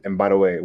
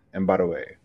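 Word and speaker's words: Mbadiwe: It's E-M-E-K-A. Real simple. And my last name is Mbadaway, which is spelled M for Mary, B for boy, A for apple, D for David, I-W-E, Mbadaway.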